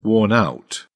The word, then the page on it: worn-out: British English